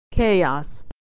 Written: chaos

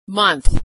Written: month

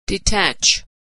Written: detach

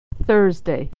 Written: Thursday

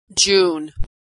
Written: June

